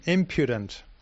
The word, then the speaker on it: impudent: How do I say it again? Is it imprudent? – Impudent. – One is not impudent.